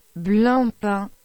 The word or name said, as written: Blancpain